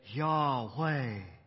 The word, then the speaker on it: Yahweh: Now, it had the letters, and we would say that name as Yahweh. And you can hear the breath, Yahweh. Yahweh.